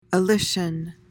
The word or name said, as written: alician